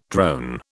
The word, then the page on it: drone: British English